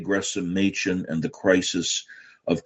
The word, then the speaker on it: Gresham: It's Gresham, incidentally. You don't pronounce the H.